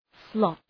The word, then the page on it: slot: slɒt